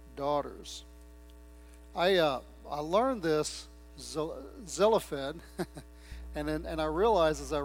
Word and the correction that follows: Zelophehad: I realize that's not the way it's pronounced. It's pronounced Zelophehad. Well, again, if you're Hebrew, you probably pronounce it a little bit different, but I had inadvertently taken a syllable out of there, and so I'm working hard to try to put it back in.